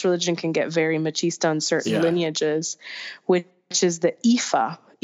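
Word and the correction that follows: Ifá: Or no, I'm sorry, I'm saying it wrong. It's Ifa. Ifa. Ifa. That's what it is. The accent's on the A.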